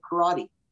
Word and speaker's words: karate: Oh, the mispronunciation in English of karate.